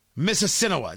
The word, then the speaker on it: Mississinewa: M-I-S-S-I-S-S-I-N-E-W-A. Mississinewa.